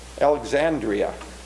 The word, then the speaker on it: Alexandria: Alexandria. I serve a church in Alexander, Iowa. This is Alexandria in Egypt.